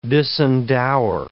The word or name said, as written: disendower